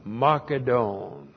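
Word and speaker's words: makedon (Μακεδών): And then we have the dative plural of the proper noun, makedon. Looks like that. M-A-K-E-D, long O-N. Makedon.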